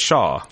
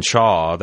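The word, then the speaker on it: Chaough: They pronounce it Shaw. When they say the full name of the company, yeah. – The company, Cutler, Gleason, and Shaw, that's what they pronounced it.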